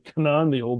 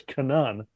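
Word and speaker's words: Canaan: I like Kanan, the old way of saying the land of Israel. There it's Kanan, land of Kanan.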